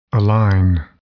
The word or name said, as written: align